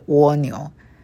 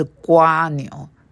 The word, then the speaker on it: 蜗牛: 蜗牛呢，因为台海两地读音不一样啊。在中国那里读的是蜗牛，在台湾那里读的是瓜牛，所以你只要知道蜗牛或瓜牛都是两边在读的就可以了。